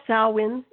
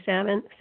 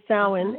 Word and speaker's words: Samhain: and pronounced Samhain, even though it doesn't look like it, and a lot of people say Samhain, because it's Samhain, but it is Samhain, and it is a celebration of the end of the harvest and the start of the coldest half of the year.